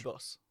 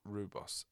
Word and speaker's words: rooibos: Rooibos. – Rooibos. Oh, I see. Rooibos, South African name.